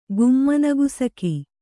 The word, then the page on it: ಗುಮ್ಮನಗುಸುಕಿ: gummana gusuka